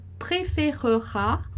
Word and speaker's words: préférera: Então, elle préférera. E aqui, muita atenção, porque você tem pré, né? Repara no acento do E ali. Pré, fé, re, ra. Préférera. Préférera. Tá?